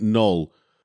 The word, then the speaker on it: Knull: And so calling him the god Null, but putting a K on the front, but pronouncing it Null, Null and Void.